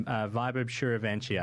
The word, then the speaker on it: Vaibhav Suryavanshi: I'm about 90% sure how to say his name. Vaibhav Suryavanshi, I think, is how you'd say it. – Yeah, you're pretty close, yeah.